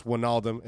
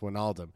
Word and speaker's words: Wijnaldum: The Dutch write a Y as an I and J put together. Thus, Wijnaldum is the same as saying, and then he spells it for us.